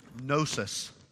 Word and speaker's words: gnosis: The Greek word that the Gnostics used for knowledge and intellect, we say it in English, gnosis. Okay, you would spell it like this, G-N-O-S-I-S.